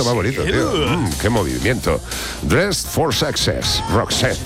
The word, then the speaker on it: Dress for Success: Hazlo tú, que eres más inglés. – Dress for Success. – ¿Ves? A que le sale mucho mejor.